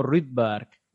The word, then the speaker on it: Rydberg: creo que lo miré que bien pronunciado era algo así como Rydberg, pero, pero vamos, yo no sé no sé noruego. – Es como Carlsberg, pero, pero Rydberg.